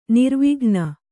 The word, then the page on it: ನಿರ್ವಿಘ್ನ: kannada